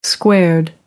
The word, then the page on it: squared: American English